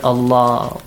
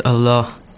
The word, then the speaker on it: Allah: When do we pronounce the word Allah with an aw sound, and when do we pronounce it with an ah sound? – When before the word Allah, there is a kasra.